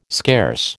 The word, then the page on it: scarce: American English